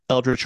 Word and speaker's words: Eldritch: Irene giving Eldritch Horror. – Oh yeah. – Sorry, let me fix that phrasing or pronunciation. Eldritch Horror.